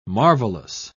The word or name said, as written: marvelous